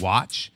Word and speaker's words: WATCH: So there's this this group called Watch. They're called WATCH, like how it's spelled, and it's World Against Toys Causing Harm Incorporated.